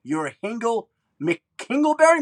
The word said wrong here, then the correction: Hingle McKringleberry: Am I saying that right? – McKringleberry. – Hingle McKringleberry, yep. His end zone celebration.